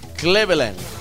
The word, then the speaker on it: Cleveland: Bueno, le cuento que en el año 1913, en una fecha como hoy, nacía o nació James Cleveland o Claveland. ¿Cómo, cómo se dice? – Cleveland. – Cleveland.